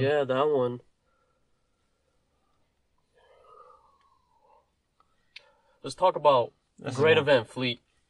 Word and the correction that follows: Greta Van Fleet: Apparently we're saying the name wrong. – What is there to talk about? – Apparently it is fucking pronounced Greta. – I forgot about them. Yeah. – Greta Van Fleet.